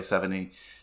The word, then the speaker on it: Sevigny: I always mispronounce her name, so if I said it wrong, I know the G is silent, but Chloe Sevigny, she's in so many things.